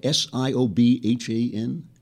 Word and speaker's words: Siobhan: All right, from Siobhan, is that how I pronounce this? S I O B H A N. Siobhan. Okay.